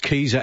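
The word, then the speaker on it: Kieser: It's K-I-E-S-E-R. Pronounced Keyser as in Geezer.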